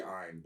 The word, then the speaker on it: Ein: I heard him say Ein, but like. – That would make sense if it was Einstein since it's a data dog. – It's spelled E-I-N. Yeah. Yeah, so it's like Einstein.